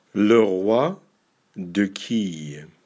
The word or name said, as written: Le roi de quille